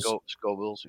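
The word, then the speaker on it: Scoville: Is that how you say it? Yeah, Scovilles? – Scovilles, yeah. Yeah.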